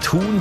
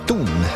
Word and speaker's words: Thun: Ohne H, gell, ausgesprochen. Mhm. Nicht Thun, sondern Thun.